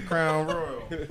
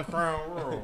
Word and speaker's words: Crown Royal: Got the crown royal. – Wait, nah, you didn't say it right. – The crown royal. – There you go.